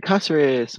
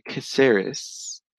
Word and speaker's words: Casares: Welcome toilet paper to the experience with Michael Aaron Kosseris, my cue. Actually, I did have a boss that pronounced my name my cue. Michael Aaron Kosseris, if you're white.